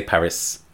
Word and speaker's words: Paris: And in many cases, they just continued to pronounce the S. Not everyone, to be fair, but a lot of countries still say Paris, especially England.